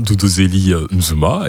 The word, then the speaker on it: Duduzile: Oui, là, tu l'as bien prononcé, c'est bien le prénom Duduzile Zuma.